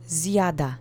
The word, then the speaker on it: zjada: The next word is zjada. It means he, she, it is eating. Accent is on the first syllable. Let's repeat it three times. Zjada, zjada, zjada.